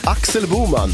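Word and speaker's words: Axel Boman: Varför fortsätter du att uttala den här mannens namn som att han är en amerikan? Det är en helt svensk. Axel Bowman.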